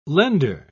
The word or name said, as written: lender